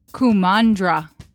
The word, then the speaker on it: Kumandra: Kumandra. Is that how you say it? – Yes. – Great! Kumandra.